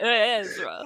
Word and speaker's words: Ezra: That's exactly how you say it. Ezra.